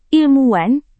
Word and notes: ilmuwan: Indonesian

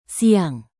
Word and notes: siang: Indonesian

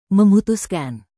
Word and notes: memutuskan: Indonesian

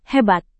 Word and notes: hebat: Indonesian

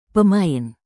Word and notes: pemain: indonesian

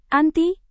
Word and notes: anti: indonesian

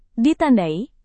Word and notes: ditandai: Indonesian

